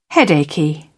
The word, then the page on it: headachy: British English